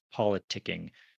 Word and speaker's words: politicking: Where does the K come from? Kind of just appears. – It seems rather random, but I guess you have to have it in there in order for it to be pronounced politicking.